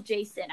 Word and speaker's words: Jacin: But it's spelled weird. Yeah. – J-A-C-I-N. – Yes. – It's still Jason, I think.